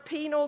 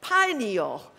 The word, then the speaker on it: pineal: But that random firing with Eddie being a professor, I just asked him as I came up here, now how do you pronounce penal gland? And he said, pineal. Think of the pine tree.